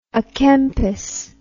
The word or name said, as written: a Kempis